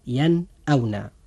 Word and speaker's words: ينأون: وهذا الحرف أخي المسلم جاء بعد النون الساكنة، فهنا يجب الإظهار وننطق هذه الكلمة هكذا ينأون.